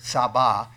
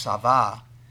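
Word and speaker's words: sava (שָׂבַע): Now, I personally feel this particular word, saba, actually pronounced sava, is a root word, I believe, directly attached to Sabbath.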